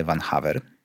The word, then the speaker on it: Van Haver: Y, będzie to pan, który nazywa się y, Paul najprawdopodobniej, to się wymawia y, Van Haver.